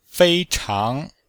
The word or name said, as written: fei1chang2